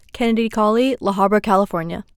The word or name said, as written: Kennadi Cauley